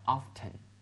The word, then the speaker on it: often: 还有一个 often 经常这个词的话，他们读成 often 的 t 发音了哈。